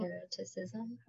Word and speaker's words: hermeticism: Hermeticism.